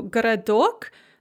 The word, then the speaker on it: городок: So городок, the first O is pronounced a, г, the second O is pronounced a, ra, and the third O, which is the one under stress, is pronounced O, городок.